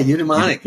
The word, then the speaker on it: eudaimonic: versus the eudaimonic, did I say that right? – Yeah, eudaimonic.